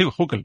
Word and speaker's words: Hückel: En alemán lleva una diéresis, sería una U. – Hückel. Una U pura, sí, Hückel, vale. – Pero algo así, suena más alemán, hay que pronunciarlo en plan alemán, claro, pero básicamente se parece mucho más a una U.